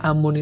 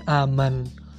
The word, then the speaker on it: Amun: Amun itu di bahasanya mereka itu sebenarnya dibacanya Aman. Nah, dari itu sebenarnya kalau sekarang dibacanya Amun tapi kalau zaman dahulu pada zamannya mereka hieroglif tuh dia membacanya aman